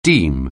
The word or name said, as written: deem